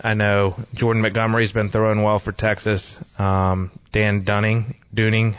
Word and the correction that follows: Dane Dunning: Dane Dunning.